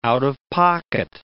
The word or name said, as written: out-of-pocket